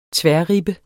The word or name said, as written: tværribbe